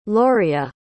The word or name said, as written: loria